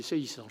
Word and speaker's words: Seissan: Ça s'écrit Seissan, mais on, dit Seissan chez nous.